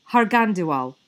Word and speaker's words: Harghandiwal: Harghandiwal.